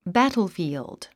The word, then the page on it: battlefield: bǽtlfìːld; バトルフィールド